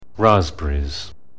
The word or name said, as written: raspberries